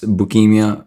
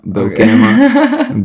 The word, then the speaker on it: Beukema: I put, I'm not sure how to say his name, looks like leukemia, probably pronounced bukemia, Beukema, Beukema, what's his Bureaucracy. – Name? – Bureaucracy. – You know Beukema?